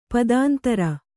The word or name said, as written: ಪದಾಂತರ